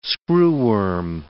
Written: screwworm

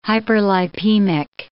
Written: hyperlipemic

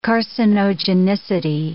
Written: carcinogenicity

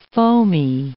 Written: foamy

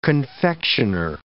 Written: confectioner

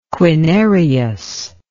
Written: quinarius

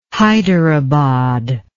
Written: Haidarabad

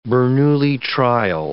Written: Bernoulli trial

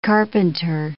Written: carpenter

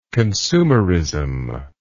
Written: consumerism

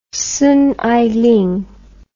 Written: Song Ailing

